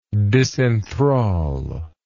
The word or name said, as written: disenthrallment